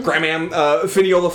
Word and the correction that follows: Finola: Flanagan. Or sorry, let me try that again. Oh, this is like an Irish name. I don't know if I'm going to pronounce this correctly. Finola. – Finola Flanagan. Yeah.